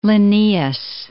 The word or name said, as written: Linnaeus